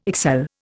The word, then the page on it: excel: Indian